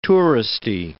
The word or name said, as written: touristy